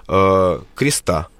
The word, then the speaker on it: Креста: Вот, да, а с другой стороны, можно с другим ударением прочитать «Креста».